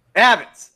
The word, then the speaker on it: Abitz: Tonight's guest is Jay Abitz. Did I say it right? – Abitz.